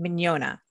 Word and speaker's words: Mignogna: The name is pronounced Mignogna. If you go to Italy, they pronounce it Mignogna. For okay. some reason, the people who immigrated to the Pennsylvania, you know, group, they it wound up morphing into Mignogna, which hurts my head and my heart so much to hear that, because it's like I hear it and I'm like, oh my god, you're pronouncing my name wrong.